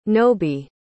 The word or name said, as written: nobie